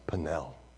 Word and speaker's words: Peniel: Properly pronounced Peniel.